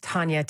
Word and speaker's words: Tanya: Again, my name is Tanya, T-A-N-Y-A.